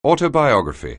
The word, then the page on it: autobiography: anglais